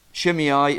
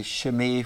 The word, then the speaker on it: Shimei: And in verse 6 to 8, this guy, now Shimei is the English way, but I'm going to say Shimei, which is the Hebrew pronunciation.